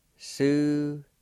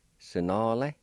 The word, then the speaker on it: sunale: Number one, morning, is pronounced like this. Su-na-le. Su-na-le. Su-na-le.